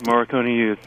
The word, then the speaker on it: Morricone Youth: And now, first of all, am I pronouncing the group's name right, or am I wrong? – It sounds correct, Morricone Youth.